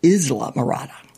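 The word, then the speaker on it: Islamorada: Nobody from outside can, but I think you're supposed to pronounce it Isla Morada. It's a Spanish word.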